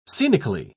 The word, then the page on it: scenically: British English